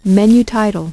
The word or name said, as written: menu title